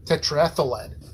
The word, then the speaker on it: tetraethyl lead: Actually, let me ask Google how this is pronounced. Pronunciation. Because I'm being very pedantic about this. Turn it up all the way. – Tetraethyl lead. – Tetraethylide. There we go.